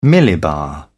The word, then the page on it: millibar: British English